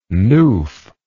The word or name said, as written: newf